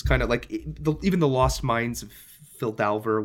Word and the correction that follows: Phandelver: Um, how's it pronounced? – Phandelver. Phandelver. Okay, yeah, it's not a good word.